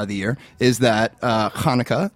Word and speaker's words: Hanukkah: Chinooka, or as we would refer to it, Hanukkah, the Festival of Lights. Thank you very much. – Is that how you say it? – Yes, Hanukkah. Now,